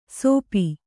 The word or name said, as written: ಸೋಪಿ